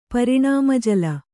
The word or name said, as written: ಪರಿಣಾಮಜಲ